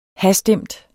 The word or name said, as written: hastemt